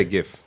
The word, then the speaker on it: GIF: I say GIF. – How about you? – GIF. – Which one is it? – I say GIF, just because Jen's with a G, with a GIF. Hard G.